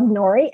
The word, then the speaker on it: Norie: So my name is spelled Nori, N-O-R-I-E, Quintos, Q-U-I-N-T-O-S.